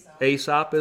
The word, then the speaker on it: Aesop: That's how you say it, right? Aesop's Fables? – Aesop, is it? Okay. The home of Aesop.